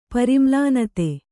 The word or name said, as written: ಪರಿಮ್ಲಾನತೆ